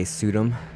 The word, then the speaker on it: Suydam: In a follow-up article, how do you pronounce his last name? Pseudonym? – Pseudonym? S-U-Y-D-A-M. So yeah. I'd say Pseudonym.